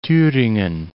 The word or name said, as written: Thuringen